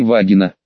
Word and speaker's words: Вагина: Меня осматривала врач-майор с немного странной фамилией Вагина, ударение на первый слог.